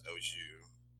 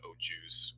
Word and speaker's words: au jus: It's pronounced au jus A lot of people say au juice or juice or whatever.